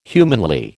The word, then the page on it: humanly: American English